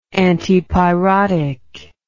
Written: antipyrotic

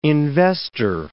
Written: investor

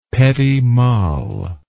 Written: petit mal

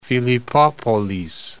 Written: Philippopolis

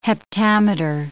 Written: heptameter

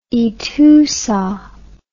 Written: ETOUSA

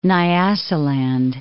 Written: Nyasaland

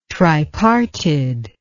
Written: triparted

